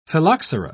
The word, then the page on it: phylloxera: British English